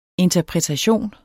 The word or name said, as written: interpretation